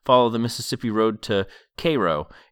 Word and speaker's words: Cairo: It's spelled like the Egyptian city, but on the page it's spelled K-A-Y-R-O, which is good to have that pronunciation, because I don't know that I would have made that if it was spelled out like Cairo.